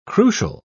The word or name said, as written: crucial